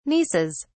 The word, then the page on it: nieces: British English